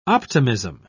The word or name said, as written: optimism